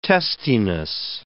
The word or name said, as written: testiness